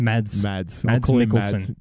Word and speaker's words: Mads Mikkelsen: Mads Mikkelsen.